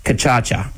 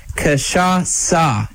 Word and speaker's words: Cachaça: An uncultured person would say Kachacha, Michael Lane, Chris Barnum, number 3 in race number 2. But it is actually pronounced Cachaça. It is a Brazilian liqueur, and its name...